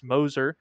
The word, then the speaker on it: Moser: Porter Moser, got to get that pronunciation right, it's Moser, becoming OU's 15th head coach, 52-year-old out of Loyola, Chicago.